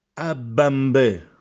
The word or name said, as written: Abbambè